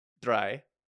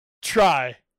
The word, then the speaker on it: drei: You may recognize some of my famous techno hits, such as Goofy Pump, Schnitzel Wolf, and my most famous, Mission Impossible, Dry. – Dry? – Dry. – Try. – Dry. – Dry. – Dry. – Can you spell that for me? – D-R-E-I. Dry.